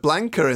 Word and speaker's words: Blanka: There's a little bit of Blanka in there. – Yeah, there is a little bit of Blanka. I'm glad you pronounced that right.